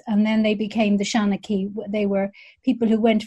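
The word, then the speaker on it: seanchaí: Shaughnessy, a traditional storyteller. Can you tell us a bit about that? – I can. It's I'm not correcting your pronunciation. I just have an alternative version. It's a Shaughnessy.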